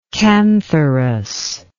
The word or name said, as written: kantharos